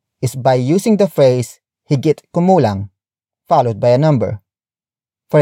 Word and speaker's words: higit kumulang: Word for word, it's higit kumulang sampu.